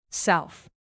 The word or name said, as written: south